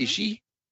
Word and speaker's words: Ishii: And, you know, but I learned that the hard syllable is the front of the name. Mm-hmm. Ishii. And then the softer syllables towards the end.